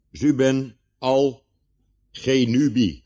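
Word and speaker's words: Zubenelgenubi: Zuben al genubi. Zuben al genubi. Gewoon op zijn Hollands uitgesproken.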